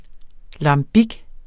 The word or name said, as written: lambic